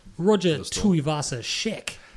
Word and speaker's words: Tuivasa-Sheck: Roger Tuivasa-Sheck. Did I pronounce that correctly? – You did, yes.